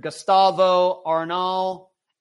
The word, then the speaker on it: Gustavo Arnal: Gustavo Arnal, A-R-N-A-L, and then Chesley Christ, this young woman.